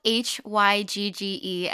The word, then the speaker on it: hygge: You talk about I'm not going to pronounce this right, but you talk about the Danish concept of hygge. Perfect. Really? Okay. So that's spelled H Y G G E.